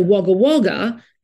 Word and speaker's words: Wagga Wagga: But Wagga, W-A-G-G-A, actually means dance. So Wagga Wagga is a place of many celebrations. And it's pronounced Wagga Wagga because we don't have the sound A. And I remember that because we were told we didn't have gangs. There's no ang.